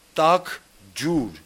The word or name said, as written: Tak-djur